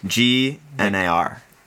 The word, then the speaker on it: Gnar: Like, so "Missiles" by Lil Gnar. I think that's how you say it. Lil Gnar. – Featuring G N A R. Yeah, Gnar. Gnar.